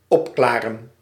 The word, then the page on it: opklaren: /ˈɔpklarə(n)/